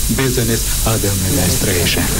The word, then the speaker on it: business administration: Nu e corect, nu ați pronunțat corect. Este business administration.